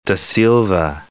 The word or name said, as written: Silva, da